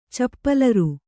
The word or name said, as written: ಚಪ್ಪಲರ್